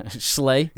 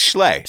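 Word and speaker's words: Schlee: Schley. – Yeah. Yeah. It's spelled S-H... S-C-H-L-E-E. – Yeah. – So it's Schley.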